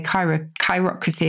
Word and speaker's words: chirocracy: So this is, the chiro is like the chiro in chiropractor. So you could say chirocracy if you like. C-H-I-R-O-C-R-A-C-Y.